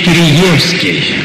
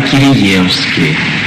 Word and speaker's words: Киреевский: Говорит, вы знаете, все-таки у нас его всегда звали Киреевский. Я бросился в словарь, смотрю, два ударения стоит. И Киреевский, и Киреевский. Но с тех пор предпочел говорить Киреевский.